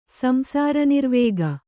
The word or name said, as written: ಸಂಸಾರನಿರ್ವೇಗ